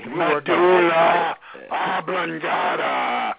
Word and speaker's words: medulla oblongata: Medulla oblongata.